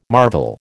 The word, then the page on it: marvel: American English